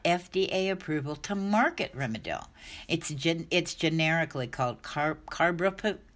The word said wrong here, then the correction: carprofen: I can never pronounce it. Carbrofen, Carprofen.